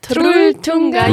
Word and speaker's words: Trolltunga: Trolltunga. You have to roll the R. Otherwise, the Norwegians don't understand you.